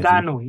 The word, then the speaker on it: Ngannou: Ngannou.